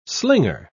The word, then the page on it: slinger: British English